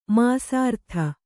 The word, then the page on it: ಮಾಸಾರ್ಥ: māsārdha